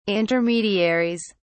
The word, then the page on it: intermediaries: British English